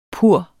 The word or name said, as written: pur